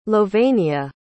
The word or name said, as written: louvenia